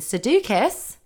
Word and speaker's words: Sudeikis: Anyway, her ex-husband, Jason Sudeikis...